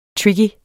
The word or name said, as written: tricky